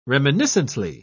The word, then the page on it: reminiscently: British English